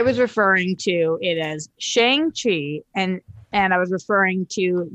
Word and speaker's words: Shang-Chi: I'm going to start referring to it as Shang-Chi. The coverage I saw said that I guess is the correct way to pronounce it. You've heard it the way I was pronouncing it is correct. Shang-Chi.